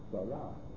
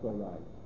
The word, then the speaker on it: Sarai: Strange, there are Galatians who pronounce like that, Sarah. They don't pronounce Sarai.